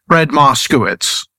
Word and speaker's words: Fred Moskowitz: Fred Moskowitz.